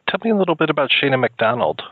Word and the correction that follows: Shona McDonald: Shona McDonald. Yeah, I said it wrong in the beginning, too, until she corrected me.